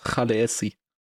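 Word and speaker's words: Khaleesi: Oder da hat sich mal dieser Sprachwissenschaftler sehr darüber aufgeregt, dass das alle falsch aussprechen, weil eigentlich ist es Chaleesi.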